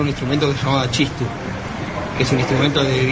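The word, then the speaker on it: txistu: Chistu, se escribe con TX. – Chistu. – Chistu.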